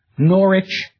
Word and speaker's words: Norwich: Or the suffix which, pronounced without the W now, like Norwich or Harwich, in the Scandinavian settled communities, this ending would have been vik or wick.